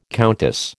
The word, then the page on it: countess: American English